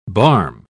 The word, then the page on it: barm: British English